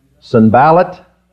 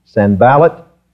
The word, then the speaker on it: Sanballat: Sanballat and Tobiah. Sanballat and Tobiah. The accent is on the middle of those, the middle syllable there.